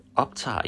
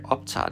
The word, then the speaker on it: optage: Nå, men dagens store nyhed er, today's big news is, at dette er min første podcast, og den optager jeg. I record it. Jeg optager den. At optage, to record. Silent G. O-P-T-A-G-E. Optage. Optage.